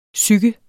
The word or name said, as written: psyke